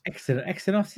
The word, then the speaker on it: Exynos: Is it Exynos? Genos? I don't know how you pronounce the Samsung ones. – Exynos, isn't it? – Exynos. So sure.